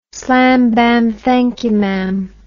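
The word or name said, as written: slam bam thank you ma'am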